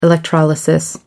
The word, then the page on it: electrolysis: American English